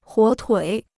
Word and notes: 火腿: huǒ tuǐ

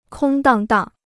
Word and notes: 空荡荡: kōng dàng dàng

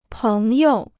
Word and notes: 朋友: péng you

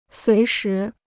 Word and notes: 随时: suí shí

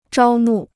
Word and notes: 招募: zhāo mù

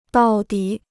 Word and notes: 到底: dào dǐ

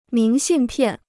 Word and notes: 明信片: míng xìn piàn

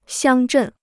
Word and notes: 乡镇: xiāng zhèn

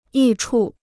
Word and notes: 益处: yì chu

